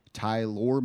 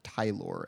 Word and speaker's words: Tyler: Is it pronounced Tylor? – It's apparently pronounced Tylor, and I don't like it.